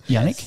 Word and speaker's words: Yanique: Yanique? – Yes, you are.